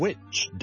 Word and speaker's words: which: 給多幾個例子大家，譬如 which W-H-I-C-H 哪一個 which，同埋一個巫婆咁啦，W-I-T-C-H， which，同埋 which，有啲人會讀成 which 嘅，so which is right，咁你可能就會聽到咧，某啲人就讀成 which is right，唔需要一定學嘅呢個音，但你可以知道點解有啲人會咁樣讀。